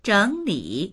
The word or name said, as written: zheng3li3